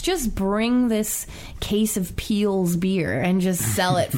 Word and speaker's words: Piel's: It's like it's spelled like PIEL'S. – Wow. – Peel's.